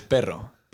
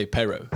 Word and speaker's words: perro: The proper way to say it is perro, but we say perro, which is kind of, I mean, it's kind of like an English speaking person would say it.